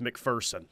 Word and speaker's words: McPherson: By the way, apparently, according to the Knippelmeyer Chevrolet text line, the locals say there's no fear in McPherson, so it's McPherson. We're getting a lot of that. Air Force Joe. McPherson is said like McPherson.